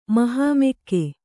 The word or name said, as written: ಮಹಾಮೆಕ್ಕೆ